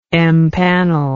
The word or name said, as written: empanel